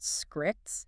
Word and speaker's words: ExcreeXX: We are exploring the source material on a platform called ExcreeXX, pronounced Scrix, and we're specifically looking at how their Shift Studio and workforce planning architecture changes this whole dynamic.